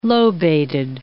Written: lobated

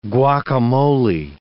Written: guacamole

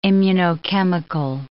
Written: immunochemical

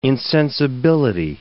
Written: insensibility

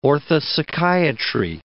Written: orthopsychiatry

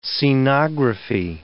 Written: scenography

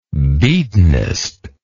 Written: beatinest

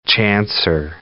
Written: chancer

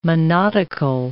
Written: monodical

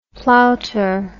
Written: plouter

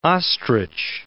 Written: ostrich

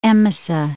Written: Emesa

